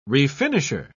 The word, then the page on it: refinisher: British English